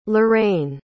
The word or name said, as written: laraine